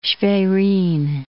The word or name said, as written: Schwerin